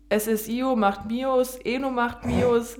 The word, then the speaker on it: SSIO: SSIO? – Ja, SSIO.